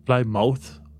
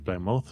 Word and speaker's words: Plymouth: Dacă un cuvânt se termină mouth, uh, de fapt tu pronunci muth. Muth. Muth. Muth. – Muth. – Deci e uh, ply mouth, ai, tu ai citit Plymouth, dar de fapt trebuie să citești Plymouth.